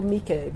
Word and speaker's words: Miike: So, I do know Miike because I used to think it was Mike with two eyes. Miike. But people are like, Miike.